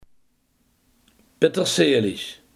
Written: peterselie

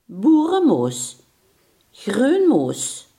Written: boerenkool

